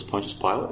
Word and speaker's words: Pontius Pilate: I used to pronounce that Pontius Pilate, right? And people must have thought I was like joking around or something. No, I just didn't know how to pronounce his freaking name. Once someone pointed it out to me that it was Pontius Pilate, I was like, all right, Pontius Pilate, you know, no problems.